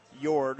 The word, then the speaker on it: Jord: I think they're still making Jord. It's a silent J, J-O-R-D, Swedish, so that you can still get your Jord watch.